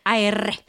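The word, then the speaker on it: Éire: E-R-I-U. In modern Gaelic, it's Ir. – Ir.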